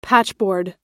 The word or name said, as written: patchboard